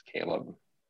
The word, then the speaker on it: Caleb: Because that's my name is Caleb with a C, not a K.